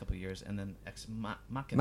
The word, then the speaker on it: Ex Machina: Ex Machina? – Ex Machina. Ex Machina. Oh, yeah. – How do you say that? – Ex Machina. – Machina. Ex Machina. – It's more Latin.